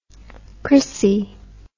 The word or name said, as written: chrissie